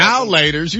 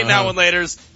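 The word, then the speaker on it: Now and Later: I say now and laters. You say now laters, like it's all one word.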